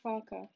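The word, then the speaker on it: Farke: Daniel Fark, what a bad season he's had. – Daniel Fark? – Yeah. – How do you say his name? – Daniel Farker, he's got a weird name.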